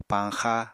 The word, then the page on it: pañxa: Apatani